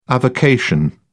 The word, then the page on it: avocation: British English